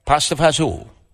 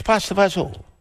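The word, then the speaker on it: pasta e fagioli: Pasta, E-F-A-G-I-O-L-I. How do you say it? – Pasta fazool. – Did we lose our guy? – It's pasta fazool.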